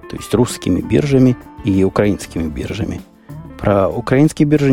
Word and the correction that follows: украинские: ничего сказать украинские. Укра... Не, украинские, точно Украина, значит украинские. Я все время не туда ставлю ударение, а называю так, как у нас в городе Жданове Мариуполе это называлось.